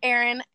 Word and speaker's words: Aaron: Like, if I would have called you that, but I'm glad to know your name's Aaron, not Arian. – Yeah, so it's pronounced Aaron.